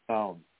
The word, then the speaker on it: Samhain: It's not pronounced that. But that's okay. That's how it was. – S-A-M-I-N. How do you pronounce it? – Song. Like your songs.